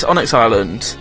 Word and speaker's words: Onyx Island: With the answer being, I believe it's pronounced Onyx Island? Yes, Onyx Island.